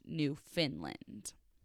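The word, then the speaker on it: Newfoundland: NL does stand for New Finland. It's pronounced New Finland.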